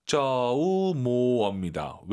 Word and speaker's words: 周末: 주말. 조모. 조모. – 아하. 应该是 조모. – 조우모입니다. 왜? 오유는 어예요. 조우모. 어로 끝내야 됩니다.